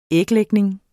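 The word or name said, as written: æglægning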